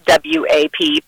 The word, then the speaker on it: WAP: It's an acronym, WAP, but it's pronounced as one word.